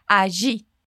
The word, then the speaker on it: allí: Essa palavrinha é escrita com dois L's, mas essa é apenas uma das várias pronúncias possíveis dela. É bastante coisa, mas agora nós vamos focar nessa que o turista usa, que é um som de J, allí.